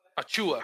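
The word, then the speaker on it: Achiuwa: Achua. – Precious. Achua, okay. Achua. – The emphasis is on the chew. – Oh, okay. Oh, that's good. I like to chew. Yeah. Precious Achua.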